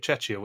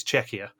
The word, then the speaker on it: Czechia: The great irony is, of course, I did get pulled up by James Turner on uh, on Twitter this week for saying Czechia wrong because I was saying it Chechia, which is how Rick kept pronouncing it. It's Czechia, and I will be saying Czechia for the rest of the season.